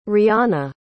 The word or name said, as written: Rianna